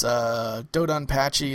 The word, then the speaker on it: DoDonPachi: Dodonpachi is the correct pronunciation.